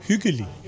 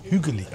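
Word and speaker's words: hyggelig: Ich habe gerade gefragt, huggelig, gemütlich, wie spricht man das aus? – Hügeli. – Hügeli. Ah.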